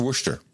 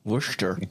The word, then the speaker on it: Worcester: She spells it like the sauce, W-O-R-C-E-S-T-R, which everyone I pronounce as Worcester, and it's just Worcester. – Worcester.